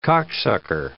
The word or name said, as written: cocksucker